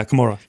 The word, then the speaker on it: Camorra: Did I say it right, sir? Yeah, Camorra.